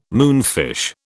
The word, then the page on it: moonfish: British English